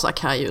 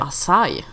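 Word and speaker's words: açaí: Vi fick ju en annan tweet också, någon som sa att det inte uttalas Akai utan Acai.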